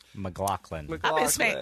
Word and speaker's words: McLachlan: And what's her name, McLachlan or McLaughlin? – McLachlan. – I've been saying her name wrong.